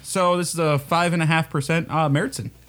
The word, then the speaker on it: Märzen: Most of the Oktoberfest that you'll find like will be a Märzen style here. – Yeah, it's spelled Marzen, but with there's an umlaut above the A, so it flattens it to Märzen.